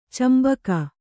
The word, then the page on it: ಚುಂಬಕ: cumbaka